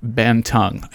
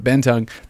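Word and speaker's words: Bantug: It's B-A-N-T-U-G. There's no extra G in there. But you know what? Born and raised in Tennessee, so I screw things up sometimes. But Ben Tung, this song is awesome.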